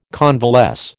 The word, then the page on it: convalesce: American English